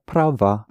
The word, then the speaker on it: права: To say she is right, again the ending will be accented. – Она права.